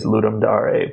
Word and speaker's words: Ludum Dare: Well, I mean, it's Latin, and I think the correct pronunciation is Ludum Dare, but, you know, no one does that.